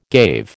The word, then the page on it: gave: American English